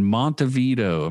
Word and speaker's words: Montevideo: I was in Uruguay in Montevideo.